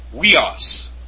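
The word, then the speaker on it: Weos (υἱός): Upsilon iota, pronounced we. Weos, extremely important word to those of the dispensation of the mystery. Weos, which means son.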